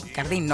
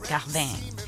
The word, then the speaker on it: Cardin: Acuérdense que la I no se dice I, Cardin, no, Cardin.